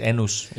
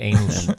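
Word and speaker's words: annus: Jeg tror, det skal udtales anus, ikke anus.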